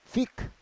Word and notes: fik: Roon

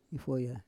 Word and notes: ifoya: Roon

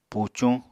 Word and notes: pucuñ: apatani

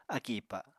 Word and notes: akì ipa: apatani

